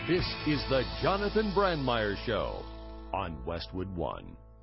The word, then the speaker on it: Brandmeier: This is Colin Jost, and you're listening to Brandmeier. I think I'm pronouncing that correctly. My German heritage is failing me. – That is correct, Colin Jost.